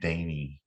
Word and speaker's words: Dante: Its goddamn name is Dante, but it's pronounced Danny. It says, you see a sign that says Dante, and every, you ask. – Is it D-A-N-T-E? – D-A-N-T, and they call it Danny Road. Well, Danny School Road. – My dad's name is Doyle, like D-O-Y-L-E, but if you ever hear anyone, if they don't call him Tater, they call it.